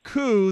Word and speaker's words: coup: Annie says, you're talking about two different French words, a coup d'etat, which is pronounced coup.